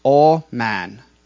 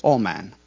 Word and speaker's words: aman: But so here in these two verses, specifically, the word that they are talking about is A-M-A-N, pronounced all man. So it's very similar to amen, but it's all man.